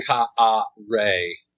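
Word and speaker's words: Kaare: That's K-A-A-R-E. – Oh, Carrie Andrews. – Is that how you say it? Carrie Andrews? – That's how I say it. – Kaar Andrews. – It's Kaar Ray.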